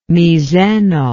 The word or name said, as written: miseno